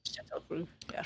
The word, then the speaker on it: Châteauroux: They have 12 Airbus A380 Super Jumbos in Châteauroux, France. How do you say that? Châteauroux? – Châteauroux, yeah.